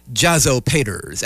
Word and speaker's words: Jazzopaters: It's how you mispronounce it that's funny, but it's literally pronounced Jazzopaters, as in the fathers of jazz.